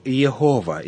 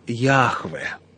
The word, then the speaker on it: Яхве: И только в середине XIX века ученые показали, что тетраграмматон следует читать как «Яхве», Однако споры до сих пор продолжаются о том, как именно это имя нужно произносить, потому что изначальный вариант был утерян.